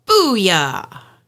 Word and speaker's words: Bulla: When you look at it, it's not spelled like that, but that's how it's pronounced. – Spelled Bulla, B-U-L-L-A.